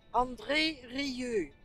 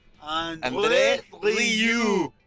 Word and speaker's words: André Rieu: Métanse a YouTube, métanse a YouTube y pónganle André Rieu, así se escribe, A-N-D-R-E-R-I-E-U, Rieu. Este, how, do you pronounce it? – Rieu. André. André Rieu. – André Rieu.